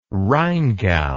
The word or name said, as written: rheingau